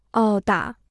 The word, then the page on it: 殴打: ōu dǎ